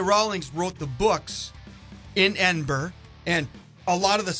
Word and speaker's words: Edinburgh: Edinburgh? – Edinburgh. Well, it looks like Edinburgh, but yeah. – You want to pronounce it like the locals, uh-huh. It's Edinburgh.